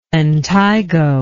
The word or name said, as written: lentigo